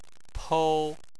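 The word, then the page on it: pou: tone 1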